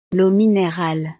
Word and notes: l'eau minerale: frances

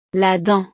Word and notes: la dent: frances

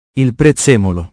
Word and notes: i prezzemoli: italiano

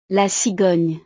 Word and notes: la cigogne: frances

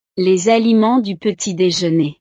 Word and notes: les aliments du petit dejeuner: frances

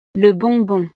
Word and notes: le bonbon: frances

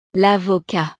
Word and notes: l'avocat: frances

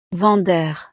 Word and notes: vendeur: frances

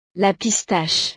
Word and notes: la pistache: frances

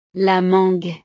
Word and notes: la mangue: French

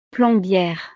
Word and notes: plombiere: frances

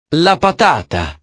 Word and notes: la patata: italiano

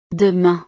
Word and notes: demain: frances

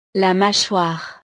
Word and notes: la machoire: French

